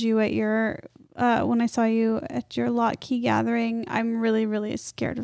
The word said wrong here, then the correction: Latke: I just have to stop you and say the way you pronounce Latka is psychotic and completely unacceptable.